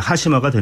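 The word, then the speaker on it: 하시마가 (端島): 한자로 이제 우리식으로 표현하자면 끝 단자에 섬 도자 단도 그거를 이제 일본식으로 읽으면 하시마가 되는데요.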